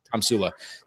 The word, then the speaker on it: Tomsula: Tomsula. I believe that's how you pronounce it. Tomsula? Tomsula.